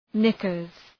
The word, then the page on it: knickers: ‘nıkərz